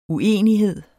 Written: uenighed

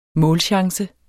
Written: målchance

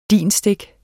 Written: DIN-stik

